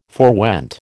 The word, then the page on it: forewent: American English